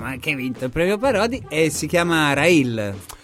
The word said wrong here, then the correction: Rahil: Ed eccoci qua mentre ascoltiamo ancora in sottofondo il brano Rahil, così si pronuncia, l'ho detto, esatto, bene, con la, questa H aspirata.